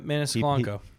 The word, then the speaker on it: Maniscalco: That Maniscalco.